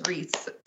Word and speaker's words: Rhys: It's Reese. – Like, how, no, like, in what fucking world is it Reese? Like, nowhere in the RHYS dictionary is that pronounced Reese.